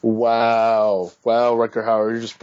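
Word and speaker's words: Rutger Hauer: Speaking of, can I just add something that I thought was hilarious was Rucker Hauer.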